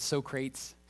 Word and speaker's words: Socrates: They can't say his name right. It's Socrates.